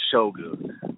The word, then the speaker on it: Shogun: Also, really quickly, to all the stubborn, dumbass listeners saying that it's not pronounced Shogun, just go on fucking Google and search Shogun pronunciation. It is pronounced Shogun.